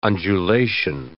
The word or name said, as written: undulation